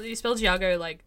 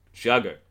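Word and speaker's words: D'Argo: You spell Jago Z apostrophe D. – Oh. – A-R-G-O.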